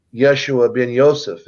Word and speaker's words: Yeshua ben Yosef: And so he came in as Jesus, which is actually Yeshua ben Yosef, is really how you pronounce his name.